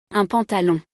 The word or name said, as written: Un pantalon